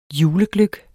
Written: julegløgg

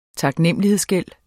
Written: taknemmelighedsgæld

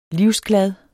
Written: livsglad

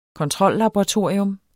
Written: kontrollaboratorium

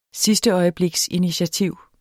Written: sidsteøjebliksinitiativ

